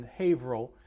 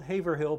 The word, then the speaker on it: Haverhill: We're in Haverhill. By the way, it's spelled Haverhill, but pronounced Haverhill.